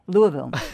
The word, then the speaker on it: Louisville: Louisville. Yep. That's where I was. Thank you. And now I don't have to say it again. – Louisville.